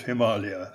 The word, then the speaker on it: Himalaya: We still use Himalayan for the adjective Himalayan, obviously, and for Himalayas for the plural, but when referring to the whole area, it's called Himalaya, and it's pronounced like that.